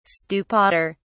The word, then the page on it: stupider: British English